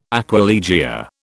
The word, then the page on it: aquilegia: British English